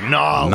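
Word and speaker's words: New Orleans: December 23rd against Nolens. Got to say it like Nolens. Got to do it that way.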